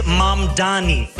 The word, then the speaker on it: Mamdani: The name is Mamdani. M-A-M-D-A-N-I. You should learn how to say it.